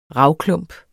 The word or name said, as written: ravklump